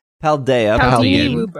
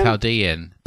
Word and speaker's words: Paldean: How do you say this? Paldea. – Paldean. – Paldean. – Paldean. – Paldean. – Like Chilean.